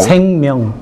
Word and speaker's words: 생명: Sengmyeong.